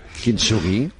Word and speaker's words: Kintsugi: Es miembro de Generación Kintsugi también, como ya lo pronunciáis vosotros, como se pronuncia. – Kintsugi. – Kintsugi, no, tal cual, bien.